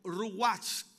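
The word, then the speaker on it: ruach (רוח): La palabra hebrea, ruach o ruach, como también deberíamos pronunciarla, es traducida viento y significa aliento sensible o también aliento violento.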